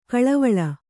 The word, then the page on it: ಕಳವಳ: Kannada